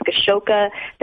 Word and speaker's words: Gashoka: So first name is Hazel and last name is Gashoka.